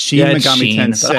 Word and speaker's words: Shin Megami Tensei: It's Shin Megami Tensei. – Obviously, it looks like the English word Shin, but so yeah, apologies if I mispronounced it in the Japanese pronunciation.